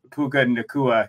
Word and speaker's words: Puka Nacua: Yeah, I'm glad you said Puka Nacua, because I was trying to pronounce that.